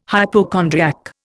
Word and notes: hypochondriac: Indian

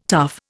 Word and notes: tough: Indian

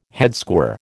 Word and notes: headsquare: American English

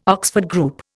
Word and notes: Oxford Group: Indian

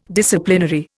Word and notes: Disciplinary: Indian

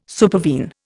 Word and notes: supervene: Indian

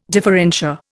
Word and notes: differentia: Indian

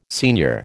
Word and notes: senior: American English

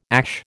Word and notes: ASH: American English

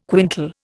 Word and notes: quintal: Indian

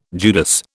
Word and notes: Judas: British English

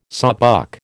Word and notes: sawbuck: American English

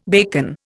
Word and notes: bacon: Indian

